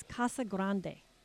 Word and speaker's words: Casa Grande: So she said the actual official Chamber of Commerce stand is that it's Casa Grande. That's the Spanish.